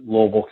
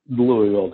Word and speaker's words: Louisville: I'm like, it's Louisville, Dad, but down there they say Louisville. So we recognize that if we're not going to be accepted as outsiders, we need to learn how to pronounce the city.